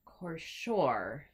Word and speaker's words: Cashore: So the next book we're going to be reading is Graceling by Kristen Cashore. – Yes. – Accent on the second syllable. – Yes. – Cashore. – Wait. Yeah, it's Kristin. Like that kind of Kristen. And then Cashore.